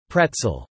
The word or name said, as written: pretzel